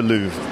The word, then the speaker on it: Louvre: So what is the Louvre? And am I pronouncing it correctly? – Yes, you're pronouncing it.